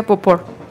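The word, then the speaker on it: Beauport: Y Elena Bauport, bueno, yo no sé francés, se pronuncia Bauport, bueno, Elaine de Bauport. – Se pronuncia Bauport.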